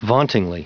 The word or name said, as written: vauntingly